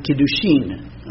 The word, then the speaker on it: kiddushin: So then we enter the first stage of a Jewish marriage, and that is called the kiddushin. I'll spell it, it's just a word. K-I-D-D-U-S-H-I-N. K-I-D-D-U-S-H-I-N.